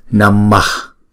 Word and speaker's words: Namah: Ví dụ như chữ Namah, chúng ta sẽ đọc tất cả là hai nhịp. Bởi vì chữ A đầu là đọc là A là một nhịp. Chữ A sau đọc là A là một nhịp nữa. Tổng cộng là hai nhịp. Namah.